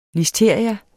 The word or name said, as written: LISTERIA